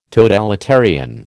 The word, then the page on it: totalitarian: American English